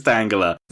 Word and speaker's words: Tangela: You have a problem with the way I say Tangela? – That one is definitely Tangela. – Tangela. Of course it's Tangela.